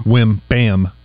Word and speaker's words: Wembanyama: We need to learn to say his name correctly. – Wembanyama. – Ban? – Yeah. – Wembanyama. Yeah. – Yama? – Yep. – Okay. Mm-hmm. Wembanyama.